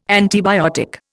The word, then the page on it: antibiotic: Indian